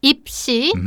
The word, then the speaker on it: 입시: Alright, so the keyword is 입시, two syllables, yep.